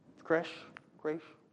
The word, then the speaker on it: creche: When we have a creche, is that how you pronounce it? Creche? Okay, a creche.